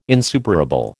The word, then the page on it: insuperable: American English